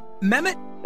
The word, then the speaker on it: Mehmet: Is it Mehmet? – That's very good. – Mehmet.